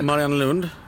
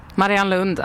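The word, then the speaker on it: Mariannelund: Nej, jag kan inte uttala det. Marianne Lund. – Marianne Lund. – Marianne Lund, jajamensan. Ja.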